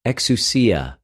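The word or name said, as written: ἐξουσία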